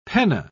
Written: penna

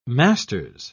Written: master's

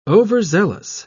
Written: overzealous

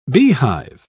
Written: beehive

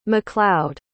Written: mcleod

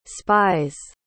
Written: spies

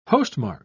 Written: postmark

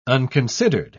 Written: unconsidered

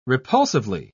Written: repulsively

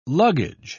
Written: luggage

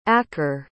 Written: akker

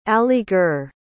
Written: aligarh

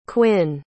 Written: cuinn

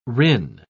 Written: rin

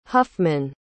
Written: huffman

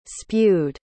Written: spewed

